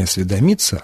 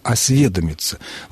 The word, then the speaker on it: осведомиться: Ну, я думаю, что, конечно, «осведомлен» и только «осведомлен», но причина вопроса, мне кажется, заключается в том, что в глаголе, однокоренном глаголе, но только возвратном, «осведомиться», ударение падает все-таки на корень, а не на суффикс. Не «осведомиться», а «осведомиться». Вот, может быть, с этим связано то, что нашего слушателя заинтересовала эта форма.